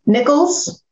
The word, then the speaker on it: Nichols: She's an adjunct instructor of advanced lean principles at Purdue University and operations management at Nicholas College. Did I butcher that? Nicholas? – Nichols. – Nichols. Boom. Nichols College.